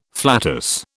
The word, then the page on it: flatus: British English